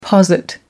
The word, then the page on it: posit: American English